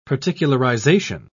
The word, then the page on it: particularization: British English